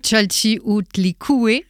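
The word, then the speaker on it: Chalchiuhtlicue: Mon challenge aujourd'hui, c'est de prononcer son nom. Chalchihuitlicue. – Oui, c'est bien.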